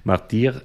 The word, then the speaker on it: Martyrs: Und das ist beim Märtyrs, äh, Martyr, jetzt habe ich schon wieder falsch gesagt, Martyr ist das natürlich, also beim Original, es gibt kein Happy End im engeren Sinn.